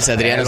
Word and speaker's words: Satriano: Satriano, Satriano, tiene buena rima.